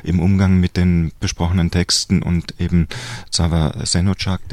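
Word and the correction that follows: Zafer Şenocak: den ich wahrscheinlich falsch ausspreche, Tuba wird mich dann gerne noch korrigieren. – Zafer Senocak.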